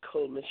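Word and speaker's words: Kuhlman: It's K-U-H-L-M-A-N. Catherine Coleman.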